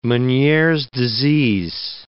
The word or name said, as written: Meniere's disease